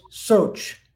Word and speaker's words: shaoca: The first one is soch, and it's different ways to spell it. Sometimes people spell it S-H-A-U-C-H-A. The Roman Sanskrit would do it S-H-A-O-C-A. The C has a ch sound. So say that other one. – S-H-A-O.